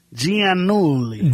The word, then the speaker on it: Giannulli: By the way, the correct pronunciation, Giannulli.